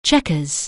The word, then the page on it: chequers: English